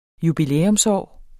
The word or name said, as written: jubilæumsår